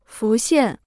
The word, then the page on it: 浮现: fú xiàn